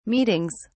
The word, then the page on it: meetings: British English